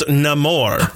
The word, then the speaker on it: Namor: It's pronounced Namor.